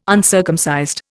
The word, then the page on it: uncircumcised: Indian